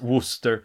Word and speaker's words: Worcester: Ja, Worcestershire sås Ja, men det uttalas Worcester.